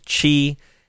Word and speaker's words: Qi: That's Qi, pronounced Qi.